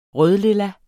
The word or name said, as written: rødlilla